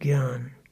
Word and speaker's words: Gyan: Gnana, of course, is the Sanskrit form, J-N-A-N-A, of the word which in Hindi and Punjabi is pronounced Gyan.